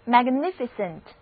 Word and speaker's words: magnificent: 单词 magnificent， M-A-G-N-I-F-I-C-E-N-T，字母 A 的发音是梅花 A，两个字母 I 的读音都是短的 E，而字母 E 不发音，整个单词重音第二个音节，一起来读两遍吧。